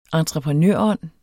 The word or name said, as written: entreprenørånd